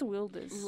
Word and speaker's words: Wilders: What I was reading up on and I thought was really interesting was that although Geert Wilders, I think it's Wilders.